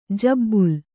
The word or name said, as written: ಜಿಬಿಲಿ